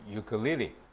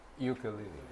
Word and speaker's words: ukulele: 淨係中意去彈嗰只咁嘅琴仔叫做 ukulele。 – How to say it? – Ukulele.